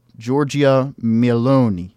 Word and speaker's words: Giorgia Meloni: So, yes, Giorgia Meloni, as they say.